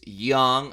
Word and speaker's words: Jung: And what it did was it allowed the Tigers to get one of the better college bats in this draft, Jace Young. I know it looks like Jung, but remember Anchorman. It's a soft J. It's Young, drafted as a second baseman technically, but that doesn't really matter.